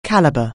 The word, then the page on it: calibre: anglais